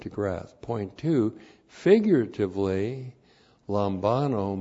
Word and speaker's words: Lambano: Lambano. L-A-M-B-A-N, long O, Lambano.